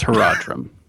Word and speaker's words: Horadrim: It's Horadrim.